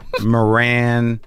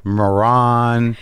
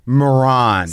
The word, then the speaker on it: Moran: It's like Baron with an M. Moran.